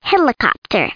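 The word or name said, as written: helicopter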